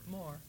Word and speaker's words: Moore: Being that you're married to Hal Moore, is it pronounced Moore or Moore? – Moore.